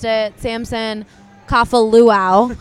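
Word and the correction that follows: Kafovalu: It was so bad, and I was just like, and right before I went on camera, I was like, Samson Kafavalu, like 50 times, and then went on camera and just said Kafaluau.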